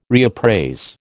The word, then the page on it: reappraise: American English